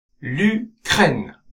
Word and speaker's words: l'Ukraine: ウクライナは女性名詞ですが、母音のうから始まるので、定冠詞のらがエリジオンして l'Ukraine と発音します。